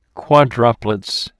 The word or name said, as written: quadruplets